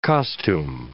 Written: costume

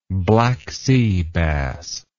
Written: black sea bass

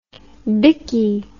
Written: bickie

